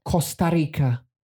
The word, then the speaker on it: Costa Rica: 我知道在哪里但是我讲不出名字 I cannot pronounce it. – Costa Rica. – Okay, 谢谢 Costa Rica.